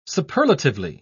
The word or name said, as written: superlatively